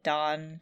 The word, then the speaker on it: Dan: And then later on still, during the war, Orochimaru comes across Tsunade trying to save her fiancé, whose name is Dan, but they pronounce it Don, so it's Don.